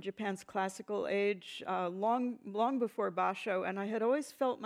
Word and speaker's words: Basho: Am I saying it right? You say Basho? – Yes, Basho.